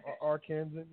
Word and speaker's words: Arkansan: But real quick, a special word from another fellow Arkansasian. Did I say that right? Did it? Mm-hmm. Arkansan? Is it? Okay. Whatever. – Okay. Arkansan? Yes.